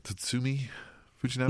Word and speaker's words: Tatsumi Fujinami: Tatsumi Fujinami? – Mm-hmm.